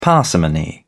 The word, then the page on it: parsimony: British English